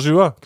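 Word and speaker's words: Saint-Gilloise: Ohne Scheiß, wie spricht man das? Gilois? Ernsthaft? – St. Gilois, klar.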